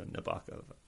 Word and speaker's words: Nabokov: There is a poem that he wrote to explain how to pronounce his name. The gawk of a loon at night prompts Nabokov to write. So you got to rhyme it with the gawk of. So Nabokov.